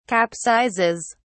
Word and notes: capsizes: British English

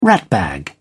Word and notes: ratbag: British English

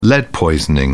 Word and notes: lead poisoning: British English

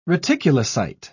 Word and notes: reticulocyte: British English

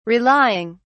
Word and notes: relying: British English

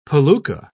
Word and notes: palooka: British English

